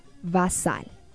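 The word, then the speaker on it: basal (بصل): Cebolla se pronuncia basal.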